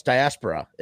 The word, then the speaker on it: diaspora: It's not diaphoria, diaspora. Yeah, no, it's diaspora. It is diaspora.